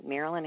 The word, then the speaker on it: Mairlyn: M-A-I-R-L-Y-N. So it's kind of rhymes with Harlan, Marilyn Harlan.